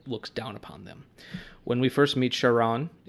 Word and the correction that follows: Chiron: is it Sharon for pronunciation? – It's either film, it's Chiron.